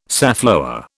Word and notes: safflower: British English

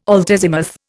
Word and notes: altazimuth: Indian

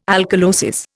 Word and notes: alkalosis: Indian

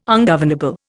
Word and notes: ungovernable: Indian

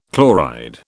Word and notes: chloride: British English

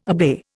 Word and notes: obey: Indian

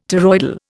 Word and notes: toroidal: Indian

